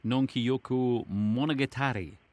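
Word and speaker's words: Nonkiyoku Monogatari (南極物語): Now, am I right in thinking it was actually inspired by a Japanese film, Nonkiyoku Monogatari? – That's correct. A nice pronunciation.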